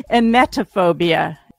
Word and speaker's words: emetophobia: I don't have emetophobia. Am I saying that right? – No. Emetophobia.